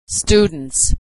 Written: students

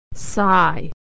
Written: sigh

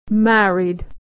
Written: married

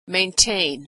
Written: maintain

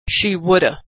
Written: she woulda

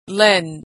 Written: lend

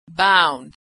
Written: bound

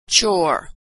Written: chore

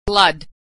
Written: blood

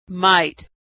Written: might

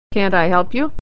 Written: can't

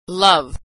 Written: love

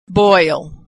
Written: boil

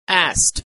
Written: asked

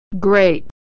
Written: great

